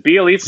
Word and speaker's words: Bjelica: How do you pronounce his name? Is it Bjelica? – Yes. I know this because I think I named my team a few years ago Bjelica Party, and it was a pizza party.